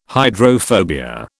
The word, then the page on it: hydrophobia: British English